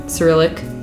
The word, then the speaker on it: Cyrillic: I also just started looking at the differences between Cyrillic. Sorry, that's a soft C, not a hard C.